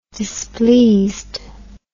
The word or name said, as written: displeased